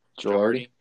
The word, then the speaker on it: Girardi: Girardi.